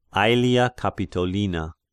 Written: Aelia Capitolina

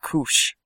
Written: Cush

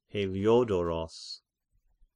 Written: Heliodoros

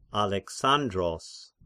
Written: Alexandros

